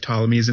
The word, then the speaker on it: Ptolemy's: Yes, you know why you never know if Ptolemy's in the bathroom with you? – Why not? – Because the P is silent.